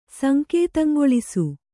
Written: ಸಂಕೇತಂಗೊಳಿಸು

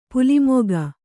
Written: ಪಲ್ಮಿಗ

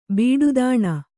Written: ಬಿಡುದಾಣ

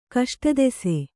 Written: ಕಷ್ಟದೆಸೆ

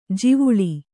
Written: ಜಿವುಳಿ

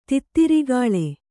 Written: ತಿತ್ತಿರಿಗಾಳೆ

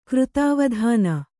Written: ಕೃತಾವಧಾನ

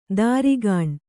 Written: ದಾರಿಗಾಣ್

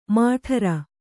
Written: ಮೇಢ್ರ

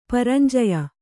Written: ಪರಂಜಯ

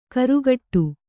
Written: ಘೊರಗುಡು

